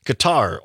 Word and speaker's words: Qatar: According to CNN and other sources, the United States and Qatar, or Gutter, as some pronounce it,